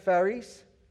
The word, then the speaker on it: charis: C-H-A-R-I-S, but it's pronounced Pharis.